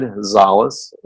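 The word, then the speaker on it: Szollose: You can Google my name, Brad Zales, and the last name is spelled S as in Sam, Z as in zebra, O-L-L-O-S-E, and it comes up.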